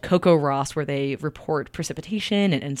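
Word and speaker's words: CoCoRaHS: Okay, this is called Coco Ross, and it's spelled in a very unique way. It's spelled Coco, like coconut, R-A-H-S, and it's short for the Community Collaborative Rain, Hail, and Snow Network.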